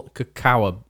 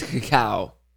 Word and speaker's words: cacao: It's Hershey's Classic Nibby Coffee ground with real cacao nibs. – Oh, it's pronounced cocoa nibs, but. – There's no way it's pronounced cocoa. – It's cacao. – C-A-C-A-O.